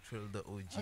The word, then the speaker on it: Trill Da OG: Trill Da OG. – Trill Da OG. Yeah. Trill Da OG. I'm saying it correctly. – Yeah, Trill Da OG.